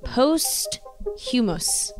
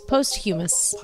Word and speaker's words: posthumous: And how could we forget the iconic Heath Ledger's performance in Dark Knight came out posthumous. Humus? Posthumous. – Posthumous.